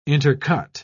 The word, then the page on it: intercut: British English